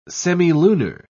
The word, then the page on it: semilunar: British English